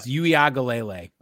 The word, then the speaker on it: Uiagalelei: Uiagalelei. – It's Uiagalelei.